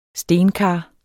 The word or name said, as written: stenkar